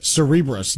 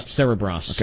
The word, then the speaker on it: Cerebras: I found out it's actually pronounced Cerebras, not Cerebras.